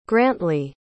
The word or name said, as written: grantley